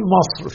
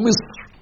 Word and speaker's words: مصر: أيضا أحبتي في الله كلمة مصر، احنا بنقول مصر في لغتنا الدارجة، مصر مصر مصر، ومصر أم الدنيا، ومصر كذا وكذا. النطق الصحيح لهذه الكلمة كما في كتاب الله جل جلاله مصر: وقال الذي اشتراه من مصر لامرأته أكرمي مثواه.